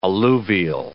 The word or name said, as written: alluvial